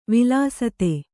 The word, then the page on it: ವಿಲಸಿತ: vilasita